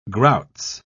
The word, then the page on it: grouts: British English